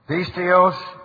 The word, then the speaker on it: pisteos: From faith, notice the G underneath it, genitive, pisteos, genitive, with the accent on the first syllable there, to faith.